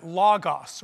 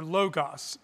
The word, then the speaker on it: logos: So the word, the concept of the word, is a Greek word for, it's a logos or logos. It's pronounced both ways in English.